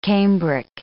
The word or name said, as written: cambric